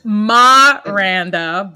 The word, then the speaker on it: Miranda: It's like, no, it's Ma-randa, but I appreciate Miranda. That. Yes. – Miranda. – But no, that's exactly it. It's the Caribbean way.